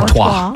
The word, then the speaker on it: Artois: Artois.